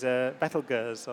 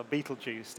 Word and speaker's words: Betelgeuse: I mean, I can tell you about a big star, physically big, which is Betelgeuse, or Betelgeuse, depending on how you pronounce it, which is the top left red star in the constellation of Orion.